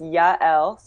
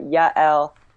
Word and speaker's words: Yael: My first name is Yael, so two syllables. I think there should probably be a little accent mark in there somewhere, but Yael, and my last name is Averbuch.